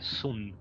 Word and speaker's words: Xunl: Y el nombre del ser ex se llama Sun, X-U-N-L. Nada más que la L dice que no se pronuncia, ¿eh? sería Sun, una cosa así.